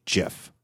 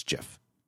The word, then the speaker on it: GIF: and the guy who invented it says that they've always pronounced it with a soft G, GIF, like the peanut butter, and therefore that side of the argument says. The guy who invented it says it's GIF, therefore it's GIF. Whereas everybody else is like, if it's graphics interchange format, it's a hard G, it's GIF.